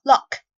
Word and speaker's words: lock: 那么第二个单词上锁锁，这里呢字母 O 它发的是短元音 O 的发音，读作 lock lock。